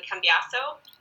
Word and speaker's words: Cambiaso: Everyone saw him consulting it and succeeding, but it turned out that he didn't have any information on Esteban Cambiaso. Is my pronunciation on that correct? – That's right, yep.